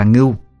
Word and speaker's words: ngưu: "Ngu đầu mã diện cụ ơi, đọc là ngưu chứ không phải là ngu đâu.